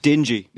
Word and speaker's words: dinghy: I believe it's pronounced dingy.